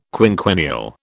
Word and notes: quinquennial: British English

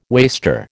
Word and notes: waster: American English

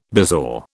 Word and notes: bezoar: British English

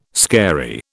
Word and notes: scary: British English

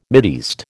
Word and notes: Mideast: American English

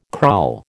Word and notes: kraal: American English

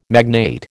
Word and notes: magnate: American English

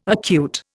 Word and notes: acute: Indian

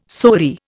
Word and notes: sori: Indian

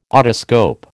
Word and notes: otoscope: American English

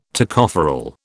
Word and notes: Tocopherol: British English

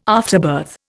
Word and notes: afterbirth: Indian

